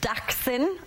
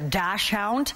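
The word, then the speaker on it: Dachshund: We got Buddy at Daxon. I better say it right because I know I've got some Daxon lovers that were not happy when I called it a dash hound, even though I had one my entire life.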